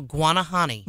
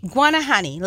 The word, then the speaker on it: Guanahani: Guanahani. – Guanahani, like iguana. Guanahani. – Sure, yeah. – Except no, I. Guanahani is written by a gentleman.